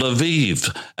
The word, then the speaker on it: Aviv: And it's called Aviv, A-V-I-V or A-B-I-B, depending upon your pronunciation.